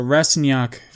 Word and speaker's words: Rastignac: So Rastignac, it's GN, right? So that's how you're supposed to pronounce it.